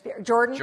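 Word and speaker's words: Jordan Spieth: And maybe what I would start doing in golf is, you know how, is it Gordon Spieth? Is that how you say his name? Jordan. – Yep.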